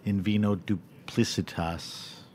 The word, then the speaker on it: In Vino Duplicitas: But you started this book, In Vino Duplicitas. – You said it right. Congratulations. – Thank you. – Not everybody does.